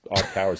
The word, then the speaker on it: iCaur: iCar, which is spelled I-C-A-U-R, but that's iCar is how they are pronouncing it.